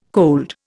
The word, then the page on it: cold: Indian